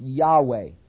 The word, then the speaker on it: Yahweh: This is the covenant name for God, YHWH, that we think is pronounced Yahweh, which comes from Exodus 3:14, which is the Hebrew verb to be, which God chose to call himself to Moses.